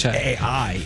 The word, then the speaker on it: Pichai: The most he did as far as talking AI was make a joke that the last two letters of his name, Pichai, are AI. Sound like AI. – It is Pichai.